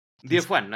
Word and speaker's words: Diefuan: porque no sé si es Daifuan o Diefuan, algo así. – Diefuan, así.